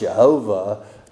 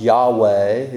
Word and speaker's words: Yahweh: It used to be pronounced Jehovah. Now, modern commentators say it should be pronounced Yahweh.